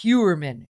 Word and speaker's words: Heuermann: How do you pronounce the last name? – Huberman, something like that. – I know. Hold on a second. – Huberman. I only heard it 100 times. – It's Huberman. H-E-U-E-R-M-A-N-N. Rex Huberman.